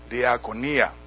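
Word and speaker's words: Diakonia (διακονία): Diakonion, and it is spelled D-I-A-K-O-N-I-A-N. Diakonion. From diakonia. D-I-A-K-O-N-I-A. Diakonia.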